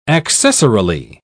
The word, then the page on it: accessorily: British English